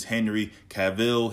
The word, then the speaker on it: Cavill: Okay, I did look at the pronunciation of his name. Is Henry Cavill.